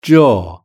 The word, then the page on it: jaw: British English